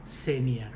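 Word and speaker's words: xenia: เซเนีย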